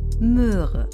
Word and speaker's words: Möhre: Aber wenn du mal genau zuhörst, merkst du, dass sie doch ziemlich unterschiedlich ausgesprochen werden. Hör mal. Moor, Möhre.